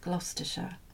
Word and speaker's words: Gloucestershire: As long as you don't pronounce it in the American way, the Oxfordshire and Gloucestershire. It has to be sh, doesn't it? – Yes.